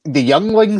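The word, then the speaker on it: Yuengling: The Yeuengling. – Spell it again. – Yeuengling. – I think it's the Youngling Center. – Youngling, yeah.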